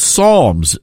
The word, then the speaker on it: Psalms: But in the Baptist faith tradition, we call that the Book of Psalms. The P is silent.